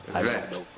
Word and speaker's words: Bet: When the letter Bet is without a dagesh, without the little dot inside of it, then the letter Bet is pronounced Vet.